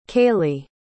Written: cailee

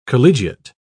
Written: collegiate

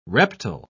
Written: reptile

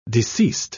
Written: deceased

